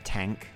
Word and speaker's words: TANGK: They've got an album coming out this Friday called Tank, which isn't... It's spelled T-A-N-G-K.